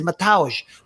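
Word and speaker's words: Mateusz: I'll say Mateus Gamrot. He says Matauj. You know what I mean? But that's probably because of where he's from.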